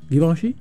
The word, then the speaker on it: Givenchy: ¿Se dice así? Givenchy. Gracias.